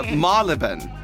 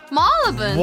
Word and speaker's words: Marylebone: Marylebone. – Marylebone. – What? – Marylebone. – Marylebone. – Yes. – What the heck? – 本当マジ言ってんの？ – マールブン。 – マールブンっていうの。 – アメリカ人はこれをマールボーンっていう風に発音するんですよ。実際はマールブン。